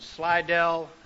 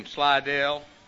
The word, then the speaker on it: Slidell: from Slidell, L.A. Is that it? – That's it. You got it. – Spent some time this afternoon coaching him on how to say the name, Slidell.